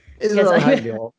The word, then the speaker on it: Israel Hayom: No, in fact, I was reading Israel Hayom today. I'm probably saying that wrong. – Israel Hayom.